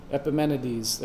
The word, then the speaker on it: Epimenides: Epimenides.